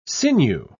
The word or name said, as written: sinew